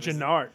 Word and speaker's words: Genard: I think of these ones, if we can move on from Antonio Callaway, the next one is Gerard Avery, I believe. – Genard. Genard? Genard? I said Gerard. It is Genard.